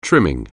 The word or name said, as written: trim